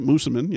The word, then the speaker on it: Moosomin: Moosomin, Saskatchewan, is that? – Yeah, that sounds right. Moosomin, yeah. – Okay, I'm saying that right.